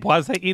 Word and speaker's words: Boise: Is that how it is all pronounced? – That's how they say it, Boise, Idaho.